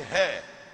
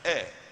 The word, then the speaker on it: heir: H-E-I-R. – Adidifu. – Some say hair, but it's not hair, it's air. – Adidifu. – The H is silent.